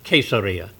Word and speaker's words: Caesarea: Here's Jerusalem, and here's the Mediterranean Sea, and you go north to the sea, and Caesarea probably should be pronounced Caesarea. It's a Latin word, and the C is pronounced like a hard K when you're pronouncing Latin. But named for Caesar, Caesarea.